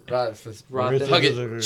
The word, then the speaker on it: Roethlisberger: Ben Roethlisberger was real happy about it. – Yeah. – I mean, I don't, did I say the name right? They? I don't think I did. – Ben Roethlisberger.